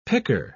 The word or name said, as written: picker